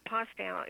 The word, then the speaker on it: Postow: It's Ruthie with an I, R-U-T-H-I. Postow is P-O-S-T-O-W, and Birch is B-I-R-C-H.